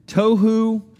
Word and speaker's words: tohu: Tohu, bohu. Like they rhyme with each other, right? T-O-H-U, B-O-H-U. Tohu and bohu.